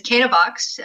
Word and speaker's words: Canavox: It's Canavox.